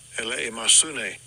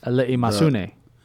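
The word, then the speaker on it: Ele masune (ἐλεημοσύνη): Strong's G 1654. Ele masune. – It's got to be right. I mean, if he says it, it's got to be right. – The way you pronounce ele masune. – Greek word for alms.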